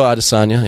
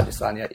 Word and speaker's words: Adesanya: I thought that Gastelum Adesanya, is that how you say his name? I've always called Israel Adesanya. Yeah. – Adesanya, yeah.